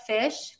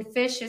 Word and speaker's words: Fisch: And then Fisch is spelled F I S C H.